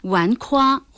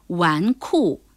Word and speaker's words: 纨绔: 句成语，之所以不明白，正是因为不知道什么是纨绔。了解了纨绔，也就不难理解为什么纨绔子弟指穿着华丽、游手好闲的富贵人家的子弟了。而你刚才的提醒也说中了我当时犯的错，纨绔还真不能读成纨夸或纨胯，而是纨绔。